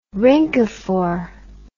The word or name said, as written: rhynchophore